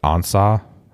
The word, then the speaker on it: Ansah: And Ezekiel, is it Ansah? Is that how you pronounce his name? – Yep. – Detroit. Yeah. Former Detroit Lions defensive end. – Ziggy Ansah.